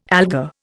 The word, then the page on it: alga: Indian